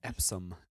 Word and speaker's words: Epsom: But I soaked my foot in some really hot water and Epsom salt. And is that how you say it? – Epsom.